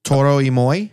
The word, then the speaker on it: Toro y Moi: Is it Toro y Moi? – Yeah, that sounds right.